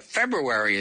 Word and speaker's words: February: August is easy, but February is strange. February is spelled F-E-B-R-U-A-R-Y and not F-E-B-U-A-R-Y, the way we all pronounce it.